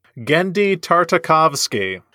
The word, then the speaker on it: Genndy Tartakovsky: Genndy Tartakovsky.